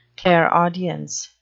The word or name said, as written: clairaudience